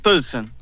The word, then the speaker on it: Pilsen: Pilsen.